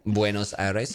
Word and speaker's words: Buenos Aires: Buenos Aires.